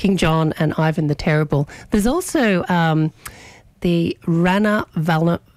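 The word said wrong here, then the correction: Ranavalona: Rana Valona.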